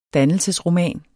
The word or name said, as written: dannelsesromaner